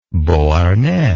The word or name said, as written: beauharnais